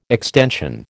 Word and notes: extension: American English

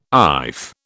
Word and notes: iff: British English

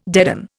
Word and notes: deaden: Indian

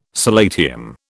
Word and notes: solatium: British English

